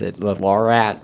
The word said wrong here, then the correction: Lariat: Lariat version.